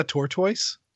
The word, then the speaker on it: tortoise: Are we talking about a tortoise? – Yes. – Yes, the tortoise. rhymes with turquoise, tortoise.